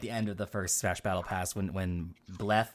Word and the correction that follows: Byleth: I don't know how to pronounce the name. – Byleth, yeah. Byleth, it's just...